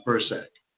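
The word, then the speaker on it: Bircsak: Call Jimmy Bursack. That's B-I-R-C-S-A-K. It's pronounced Bursack.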